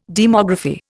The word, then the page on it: demography: Indian